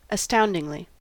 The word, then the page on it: astoundingly: American English